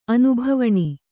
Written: ಅನುಭವಣಿ

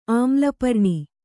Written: ಆಮ್ಲಪರ್ಣಿ

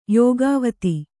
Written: ಯೋಗಾವತಿ

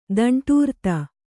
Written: ದಂಟೂರ್ತ

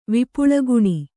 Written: ವಿಪುಳಗುಣಿ

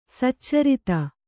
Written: ಸಚ್ಚರಿತೆ

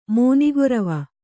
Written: ಮಾನಗರ್ವ

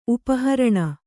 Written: ಉಪಹರಣ

